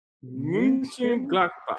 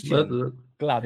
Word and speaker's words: Mönchengladbach: It's That's the correct. München Gladbach. Yeah. Okay. – It's quite the same as Bayern Munich or Bayern München. It's spelled a bit different, but you can say München Gladbach. It's way easier.